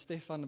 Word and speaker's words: Stefan: Sorry, my name's Stefan, so I keep on. And usually Stefan is spelled PH, and mine's F, and it's Stefan, but he's F, but it's Stefan.